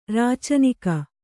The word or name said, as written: ರೌಚನಿಕ